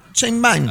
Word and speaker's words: Sheinbaum: Sheinbaum. – Ah. – ¿O cómo se dice? No, no, no, correcto.